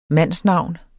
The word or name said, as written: mandsnavn